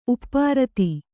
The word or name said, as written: ಉಪ್ಪಾರಿತ